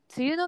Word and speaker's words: 梅雨: 梅の花の梅に雨と書いて梅雨と読みます。梅雨の期間に入ることを梅雨入り、梅雨の期間が終わることを梅雨明けと言います。